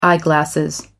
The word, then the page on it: eyeglasses: American English